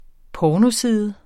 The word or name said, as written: pornoside